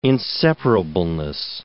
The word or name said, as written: inseparableness